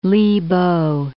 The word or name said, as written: Li Po